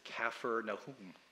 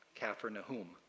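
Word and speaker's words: Kfar Nahum: Some of the times we call it Capernaum or Capernaum, but while we were there, we learned that the correct pronunciation, at least by those in that land, is Kafir Nahum, after the Old Testament prophet Nahum, Kafir Nahum.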